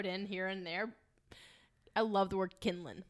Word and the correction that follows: kindling: Is it not spelled kindling? – I don't know how it's spelled, but I know it's pronounced. It's spelled kinlan. Oh, what did you God. Say? Kinkling? – Kindling. K-I-N-D-L-I-N-G.